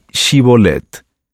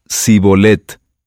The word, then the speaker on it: Shibolet: Si él respondía: No, entonces le decían: Ahora pues, di Shibolet. Y él decía: Sibolet, porque no podía pronunciarlo de aquella manera.